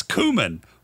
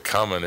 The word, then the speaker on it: cumin: It's cumin. What the fuck is that about? – And I thought you pronounced it coming anyway. – No, that's something totally different. – No, I'm being serious. I'm not saying it honorary. I really thought it was pronounced coming and everybody's saying cumin.